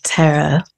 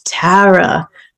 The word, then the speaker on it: Tara: Well, I used to say it like Tara, but then when I moved here, I was informed by Roisin Garvey that I was saying it wrong my whole life. – Okay. – And she said, no, it's Tara.